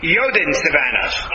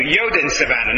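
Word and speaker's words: Joden Savanne: Yoden Savannah. Okay, thank you. Yoden Savannah, not Yudin.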